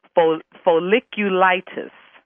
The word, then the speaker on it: folliculitis: And I wrote it down phonetically so I can pronounce it. Folliculitis. – You got it right.